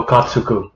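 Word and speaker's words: Okkotsu: Yuta Okatsuku. – Okatsuku, is that how you say it? – So something like that. – Sure. – No, just Okatsu.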